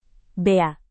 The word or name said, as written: bea